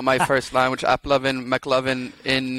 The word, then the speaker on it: AppLovin: AppLoving, that's how you say it, not loving. So there's no N there, there's no ing.